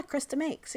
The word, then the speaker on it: Chris DeMakes: After that, I went to go see, I don't know if I'm saying his name right, and I never have, and I might never know, but Chris DeMakes from Lesson Jake. – Yeah, Chris DeMakes, yeah.